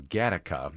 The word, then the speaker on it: Gattaca: Well, number three on your list of sci-fi movie moments that came true is from Gattaca. Am I saying that right? – Yes.